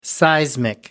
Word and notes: seismic: American English

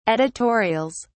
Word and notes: editorials: British English

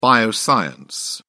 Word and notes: bioscience: British English

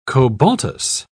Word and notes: cobaltous: British English

